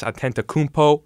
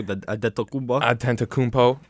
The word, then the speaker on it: Antetokounmpo: Number 3, the Greek freak, Giannis Antetokounmpo. That was a pretty good pronounce. – Yeah, I would say so. Antetokounmpo.